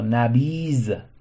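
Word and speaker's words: نبیذ: نبید. این غزل با زال تلفظ میشه بر طبق تلفظهای قدما، یعنی میگفتن دمیز و با نبیز هم قافیه میکردن.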